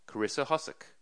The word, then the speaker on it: Karissa Hosek: I'm here, actually, with my second guest of the day, Karissa Hosek. How did I go? Did I do okay? – Yeah, you did great. – And the pronunciation, since I was terrified? – It's great. Yeah, you did just fine.